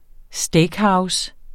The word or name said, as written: steakhouse